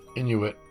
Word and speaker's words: Inuit: Inuit.